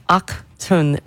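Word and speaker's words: Achtung: Achtun Baby. I was today years old when I realized it's pronounced that way because it's German.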